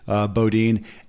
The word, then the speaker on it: Beaudine: And I don't want people to miss, you talking about Bob, Bodine, and folks, his name is spelled B-E-A-U-D-I-N-E.